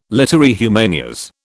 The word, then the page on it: literae humaniores: British English